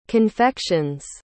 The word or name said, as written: confections